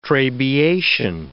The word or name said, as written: trabeation